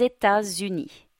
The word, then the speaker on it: États-Unis: They should know how to pronounce the name of their own country in French, even if it's not as obvious as it seems. Les D aux États-Unis have two liaisons, one at the beginning, Zeta, one in the middle, Zuni.